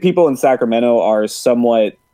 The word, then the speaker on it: Sacramento: Yeah, actually, you're the one person I've ever met from outside of this area that actually pronounces Sacramento correctly. Everyone says Sacramento, but we hear Sacramento. Yeah, the T is silent. People don't know this, but you can always tell. Nobody says the T.